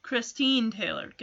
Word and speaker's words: Christine Taylor: Christine Taylor.